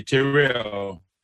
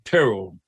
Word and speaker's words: Terrell: And she would always say, sometimes I slip and I say, "Terrell." She said, "No, it's Terrell."